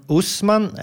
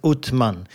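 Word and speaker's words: Osman: Det kommer från Usman, eh, som också kan uttalas Utman.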